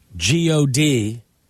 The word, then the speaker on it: G-O-D: I'm running, but it says here the stunt was part of a Wu-Tang tribute music video by local rapper Dupree G-O-D. I'm pronouncing it with the letters because there's a period between the G, the O, and the D. – Yeah. – According to the New York Post. – Dupree G-O-D, Wu-Tang forever, Wu-Tang's for the children.